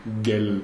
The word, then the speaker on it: Miguel: Miguel, you write it with G and U, G-U-E, but you only pronounce G and E, Miguel.